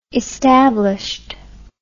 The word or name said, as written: established